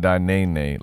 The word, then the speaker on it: Danene: My name is pronounced Danene. Like, watch me whip, watch me nene.